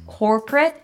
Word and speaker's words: corporate: Today, I want to talk about the two words corporate and cooperate. Corporate, cooperate. The first word has two syllables. The first one is core. Core, just like the word core or for. Core.